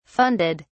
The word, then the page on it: funded: British English